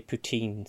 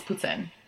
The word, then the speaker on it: poutine: I always say poutine, so. – Okay, maybe. Well, it's poutine. You're wrong. Learn how to say it, please.